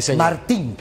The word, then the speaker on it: Martín: Martín con apellido, ah. con acento en la I. Sí, Martín.